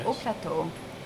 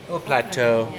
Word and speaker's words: Haute Plateau: My vote is for the Provençal Haute Plateau. Am I saying that right? – Oh yeah, Haute Plateau. – Haute Plateau. – Yes, perfect.